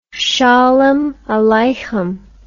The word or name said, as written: sholem aleichem